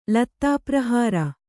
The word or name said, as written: ಲತ್ತಾಪ್ರಹಾರ